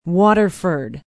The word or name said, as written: waterford